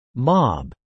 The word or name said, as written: mob